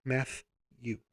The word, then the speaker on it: Mathew: There's literally not two T's in my Matthew. It is M A T H E W, which is also phonetically correct. Math you.